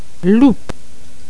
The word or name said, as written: loupe